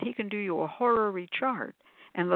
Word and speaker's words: horary: So horary chart, despite the sound of the name, is spelled entirely different. H-O-R, whatever, A-R-Y, horary chart.